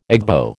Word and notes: Igbo: American English

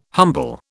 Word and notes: humble: British English

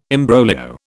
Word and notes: imbroglio: British English

